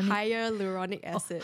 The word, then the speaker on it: hyaluronic: And the skin, like, parts of my face is actually filled up with hyaluronic acid. – Oh. – Yeah, otherwise, there's, like, a literal dent there. – What was I don't even know how to pronounce it. Hyaluronic. – Hyaluronic acid.